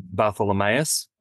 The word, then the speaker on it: Bartholomaeus: My family says the last name Bartholomaeus, but I imagine that's just because that's how it's spelt. And no one in Australia 150 years ago knew how to read the name one way and say it another.